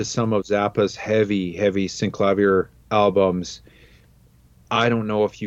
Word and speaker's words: Synclavier: Synclavier? – Synclavier. That's how Henry pronounces it.